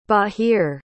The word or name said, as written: bahir